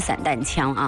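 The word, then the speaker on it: 霰弹枪: 但是在读炮弹的时候，读的就是应该读散这个音，散弹啊，散弹枪。我们回复一下微信当中的这位叫微风过海，还有临江仙，还有一位呢没有标注名字的这位微信听友。 – 所以啊，它这个正确的读音呢就是散弹枪啊。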